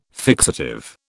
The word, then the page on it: fixative: British English